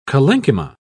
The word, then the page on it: collenchyma: British English